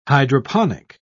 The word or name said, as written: hydroponic